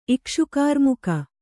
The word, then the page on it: ಇಕ್ಷುಕಾರ್ಮುಕ: Kannada